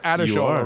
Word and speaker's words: Adeshola: It's Adeshola, right? – You are.